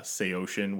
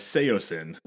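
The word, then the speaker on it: Saosin: I got a lot of just the Anthony Green Project's old Say Ocean, which is how that's pronounced, which ruined my entire, like... I used to listen to them and refer to them as Seosin.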